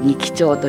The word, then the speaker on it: 仁木町: 仁木町はね、果物で有名な町なんですけれどもね、仁義の仁に、木と書いて仁木町と読むんですが、さくらんぼの花、白いお花が満開でした。